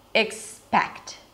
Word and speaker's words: expect: Listen to the difference, accept, expect. Here, the difference is in the second syllable. So it begins the same, except, the second syllable is sept, and in the word expect, the second syllable is spect.